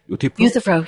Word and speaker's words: Euthyphro: Euthyphro, I hope I'm pronouncing that right? – Euthyphro, yes.